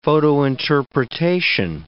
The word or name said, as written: photointerpretation